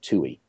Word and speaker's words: Tuohey: And one of the guys, the number two guy in the Starr investigation, was a guy named Mark Toohey. It's spelled T-U-O-H-E-Y.